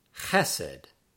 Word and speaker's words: chesed: Number 59, the word for love or loyalty is chesed, chesed, chesed. And the accent on that word is on the first syllable.